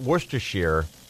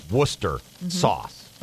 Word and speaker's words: Worcestershire: But Boyer maintained all along that it's not Worcestershire. It's just pronounced Worcester sauce. – Right. – And I was like, well, what about the S-H-I-R-E? You don't pronounce it. And he maintained because it's named after the city in England, Worcester, where it's made.